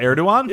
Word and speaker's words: Erdogan: What, Erdogan? – Yeah.